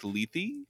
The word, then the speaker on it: Lethe: The, I think, Lethe is yes.